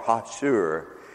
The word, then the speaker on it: Hatsur: It's spelled in the Hebrew, H-A-T-S-U-R, Hashur, and it's the rock.